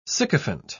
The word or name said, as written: sycophant